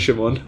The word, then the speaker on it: Shimon: I just say, if you can pronounce Pokemon, my name rhymes with that. Just call me Shimon.